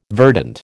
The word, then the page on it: verdant: American English